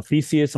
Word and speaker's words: Theseus: Some poignant lines from, I don't know, Theseus, am I pronouncing that correctly? – Yeah.